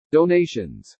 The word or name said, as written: DONATIONS